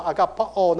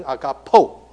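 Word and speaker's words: agapo (ἀγαπῶ): 所问的这个字用的动词叫做 agapo，你可能听过爱加倍这个字 agape，可是 agape 是名词，所以正确的用动词应该叫 agapao。可是如果有人讲说 agapao 呢，可能他的希腊文学的不太好，因为那个叫 contract verb， omega 结尾的 contract verb 要念成 agapo。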